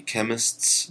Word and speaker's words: Qemists: So with that in mind, here is my first song by The Chemists. That's spelled Q-E-M-I-S-T-S with guest vocalist Mike Patton, and this is a track called Lost Weekend.